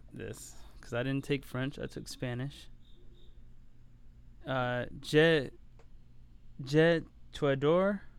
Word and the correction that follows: Je t'adore: is that right? I love you. Do you know? – I took 3 years of French and can barely speak a fucking word of it. – Je t'adore. That's how you say it.